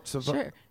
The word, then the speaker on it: Savone: Your last name is Martinez, but it's pronounced Savone? – Yeah.